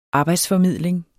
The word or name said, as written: arbejdsformidling